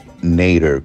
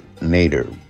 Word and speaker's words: Nater: Me lo han puesto Neira también, este N-I-E-D-A, Neira. Entonces, lo que pasa es que en inglés mi apellido Mm, lo, pronuncia, lo pronuncian nader, nader como later, nader, porque se escribe como la palabra en inglés later, L-A-T-E-R.